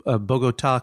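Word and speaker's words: Bogotá: Now, we reach you in Bogota, Colombia. Is that correct? – Yes. – And am I pronouncing that correctly? – You pronounce it pretty well.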